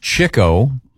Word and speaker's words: Chico: It's pronounced Chico. Really? – Spelled Chico. – I've never heard that. – It's spelled Chico, one. – But it's Chico.